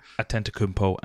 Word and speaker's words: Antetokounmpo: Antetokounmpo. – I'm going to have to get used to that. Antetokounmpo.